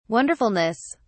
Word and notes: wonderfulness: British English

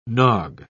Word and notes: nog: British English

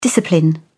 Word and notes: discipline: British English

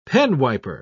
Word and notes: penwiper: British English

